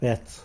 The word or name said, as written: bets